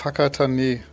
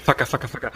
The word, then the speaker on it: Whakatane: Ja, in dem schönen Ort Whakatane.